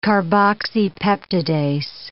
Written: carboxypeptidase

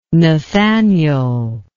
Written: Nathaniel

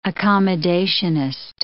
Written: accommodationist

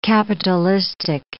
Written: capitalistic

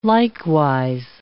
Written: likewise

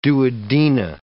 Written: duodena

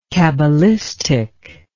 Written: kabalistic

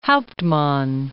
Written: Hauptmann

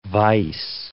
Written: vice

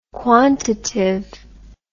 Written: quantitive

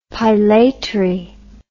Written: pilatory

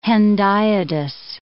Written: hendiadys

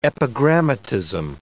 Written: epigrammatism

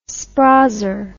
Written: sprazer

